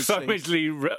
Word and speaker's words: Midgley: Simon Midgley rhymes with Ridgely.